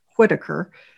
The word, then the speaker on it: Whitaker: So Brian Whitaker, and it's spelled like Whiteacre, Whitaker.